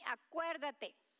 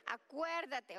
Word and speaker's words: Acuérdate: Close your eyes, because if you see it, you're going to try to pronounce it like if it is in English. So just say it after me. Acuérdate. Acuérdate.